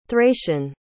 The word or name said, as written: thracian